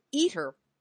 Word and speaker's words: ITER: International Thermonuclear Experimental Reactor, or ITER, pronounced ITER, project at the Oak Ridge National Laboratories.